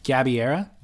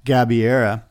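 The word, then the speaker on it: Gabeira: Gabiera. – Gabiera.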